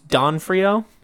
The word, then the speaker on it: D'Onofrio: Vincent I believe it's Don Frio. Don Frio.